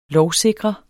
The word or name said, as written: lovsikre